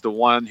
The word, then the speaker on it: Theon: Am I saying that right, Theon? – Yeah.